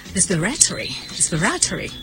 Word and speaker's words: respiratory: คำศัพท์ที่เกี่ยวข้องกับการหายใจภาษาอังกฤษบอกว่า relating to breathing เนี่ยนะคะเราใช้คำนี้ค่ะมาดูตัวสะกดก่อนนะคะ R E S P I R A T O R Y